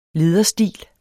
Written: lederstil

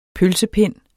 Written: pølsepind

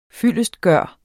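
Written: FYLDESTGØRES

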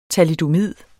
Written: thalidomid

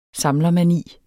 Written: samlermani

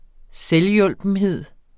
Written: installationsprogram

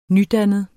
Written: nydannet

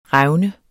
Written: revne

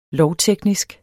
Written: lovteknisk

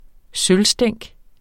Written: sølvstænk

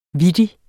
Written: vittig

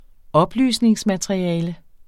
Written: oplysningsmateriale

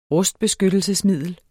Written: rustbeskyttelsesmiddel

